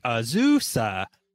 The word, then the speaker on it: Azusa: Someone commented on something that we posted explaining the proper way to say it is like an old bicycle, like Azusa.